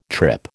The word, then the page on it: trip: American English